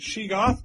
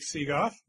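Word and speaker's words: Sheegoth: What is a Seagoth? – Yes, I pronounce it Shigoth, but I'll take Seagoth.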